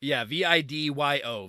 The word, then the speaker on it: Vidyo: V-I-D-Y-O.